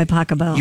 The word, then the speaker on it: Pachelbel: Cannon D by Pasquale. – By Pachelbel. – You pronounce it Pasquale? – No.